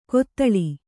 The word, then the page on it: ಕೊತ್ತಳಿ: kottaḷi